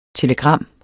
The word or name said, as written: TELEGRAMMERNES